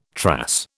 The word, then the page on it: trass: British English